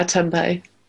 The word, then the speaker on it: tempeh: But Sainsbury's are really good, but some of the tempeh, is that how you say it? – Yeah, tempeh.